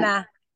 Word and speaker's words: hermana: yeah, The H is silent. – Hermana, Hermana, Hermana.